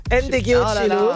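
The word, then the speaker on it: Ndegeocello: Autrice, compositrice, chanteuse américaine, Michelle Ndegocello. – Ndegocello.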